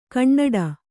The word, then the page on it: ಕಣ್ಣಡ: kaṇṇaḍa